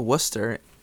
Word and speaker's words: Worcester: I think she settled on W-H-O-O-S-T-E-R. And we had to explain to her, no, Worcester, Mass, is actually spelled W-O-R-C-E-S-T-E-R.